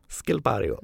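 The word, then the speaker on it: Schilpario: Skilpario. – Skilpario. Jag vet inte, i norra Italien så uttalar man det kanske på tyska. – Ja, men jag tror det är K-ljud på det.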